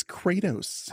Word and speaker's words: Kratos: It's Kratos.